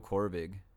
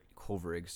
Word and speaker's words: Kovrig: I saw that Canada also recently contacted Michael Kovrig, which is good. – Oh, Kovrig. – Kovrig, sorry.